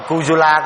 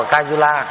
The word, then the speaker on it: Kujula: Thì ghi về cái triều đại quý xương á là có mấy nhân vật ạ, có chỗ đọc là Kujula, có chỗ đọc là Kajula, có chỗ ghi là Vima, có chỗ ghi vậy, có chỗ ghi V, có chỗ ghi W, Vima Tattu.